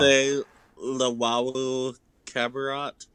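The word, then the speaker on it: Timothé Luwawu-Cabarrot: Timothée Luwawu-Cabarrot.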